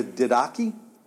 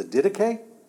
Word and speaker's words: Didache: There's an early Christian document called the Didache, or the Didache. I'll spell it for you. Spelled like this. D-I-D-A-C-H-E.